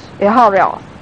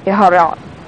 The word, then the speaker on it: Iscariot: It was Judas Iscariot. Iscariot. It's pronounced either way.